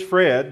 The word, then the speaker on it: Fred: Well, my name is Fred, and if you don't know how to spell it, it's F-Red, F-R-E-D, and we're glad to be with you today.